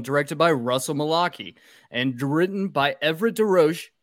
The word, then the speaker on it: Mulcahy: It's Mulcahy.